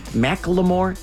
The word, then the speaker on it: Macklemore: I thank you for explaining that to me because I was kind of Macklemore.